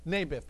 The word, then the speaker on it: Naboth: Naboth.